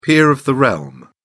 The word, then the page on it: peer of the realm: British English